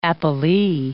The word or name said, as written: appellee